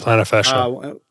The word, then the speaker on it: plantar fascia: Plantar fascia.